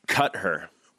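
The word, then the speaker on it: Qatar: It's pronounced, cut her.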